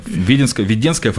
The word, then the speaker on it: Веденская: А Веденская, я правильно ударение Веденская. – Ставлю? Веденская. Веденская, да? Веденская.